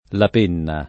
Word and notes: La Penna: lingua italiana

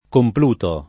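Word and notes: Compluto: italiano

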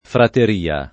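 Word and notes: frateria: italiano

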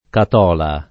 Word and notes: Catola: lingua italiana

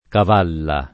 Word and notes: Cavalla: lingua italiana

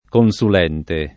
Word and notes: consulente: Italian

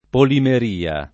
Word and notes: polimeria: lingua italiana

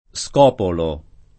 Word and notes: Scopolo: italiana

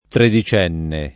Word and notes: tredicenne: lingua italiana

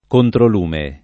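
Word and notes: controlume: italiano

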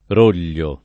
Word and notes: Roglio: italiana

